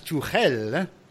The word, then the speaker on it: Tuchel: Another big hot topic is Thomas Tuchel, which is apparently the right pronunciation.